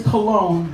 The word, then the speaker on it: Colon: My last name is Cologne, but it is spelled C-O-L-O-N. And so usually when you read it off the paper, everyone says Mr. Colon.